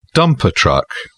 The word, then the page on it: dumper truck: British English